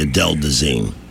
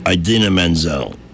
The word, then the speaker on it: Idina Menzel: Idina Menzel, not Adele Dazeem.